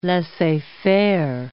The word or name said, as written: laissez-faire